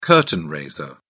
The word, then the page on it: curtain raiser: British English